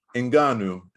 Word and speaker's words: Ngannou: Francis, the Predator, Ngannou, and that's how you pronounce his name, not Ngannou or Ngannou or whatever.